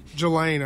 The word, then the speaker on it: Ghislaine: G-H-I-S-L-A-I-N-E. Ghislaine? We'll call her Ghislaine. Maxwell. – Jelaine, I'd say. – Jelaine? The S is silent then. – Yeah. – Okay. It's not just Lane.